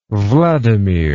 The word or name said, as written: wladimir